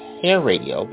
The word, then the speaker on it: HAIRadio: Now it's pronounced Hair Radio, but it only has one R.